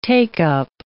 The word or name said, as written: take-up